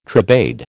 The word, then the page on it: tribade: American English